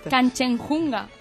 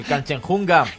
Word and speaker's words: Kanchenjunga: Pero lo ha conseguido, ha subido al Kanchenjunga, que es la tercera montaña más alta del mundo. Y es uno de los 8.000 que está haciendo todos. – Es difícil hasta pronunciarlo. – Kanchenjunga. – El Kanchenjunga.